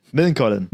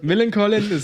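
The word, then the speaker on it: Millencolin: Und ich würde auch, wie werde ich nochmal ausgesprochen? Melancholin? – Millen Colin. – Millen Colin, klingt wie so ein Doppelname aus Millen und Colin.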